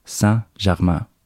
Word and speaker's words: Saint-Germain: Så for å få hjelp med dette, så snakket jeg med en kollega som fransklærer, og uttalen skal være noe I retning av Saint-Germain.